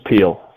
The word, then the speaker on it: Peele: It's Peele.